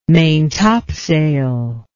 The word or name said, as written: maintopsail